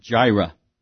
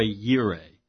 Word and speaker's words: Yireh: Some people pronounce it Jireh, but it's Yahweh Yireh.